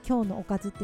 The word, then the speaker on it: 今日のおかず: また、えー、今日のおかず、ね、 KYO の OKAZU で今日のおかずって読むんですけれどもの YouTube チャンネルもあります。